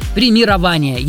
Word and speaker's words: премирование: Согласно орфоэпической норме русского языка, ударение в нем все-таки следует ставить на четвертый слог и говорить правильно «премирование».